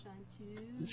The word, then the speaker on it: chanteuse: Is that how you pronounce it? – And if we are in France, it is chanteuse.